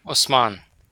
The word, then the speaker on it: Usman: Usman.